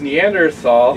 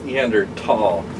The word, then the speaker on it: Neanderthal: And I hate how he pronounces it because he always goes Neanderthal. Neanderthal. I like to say thal.